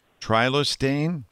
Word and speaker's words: Trilostane: All right, Laura and Tom and Robinson want to know, our dog was placed on a drug named Trilostane for his...